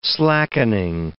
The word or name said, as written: slackening